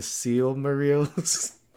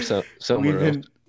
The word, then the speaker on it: Silmarils: I think it's actually, they're actually pronounced the Seal Marils. – Oh, well, I don't care. I'm going to call them Silmarils.